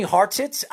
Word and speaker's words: Hartsitz: And I'll tell you this, Ian Hartsitz, he told me Hartsitz. It was very hard to pronounce his name because he says the T and the I is kind of like, it's not silent, but it's there. So it's like a roll kind of thing.